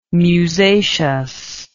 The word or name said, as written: musaceous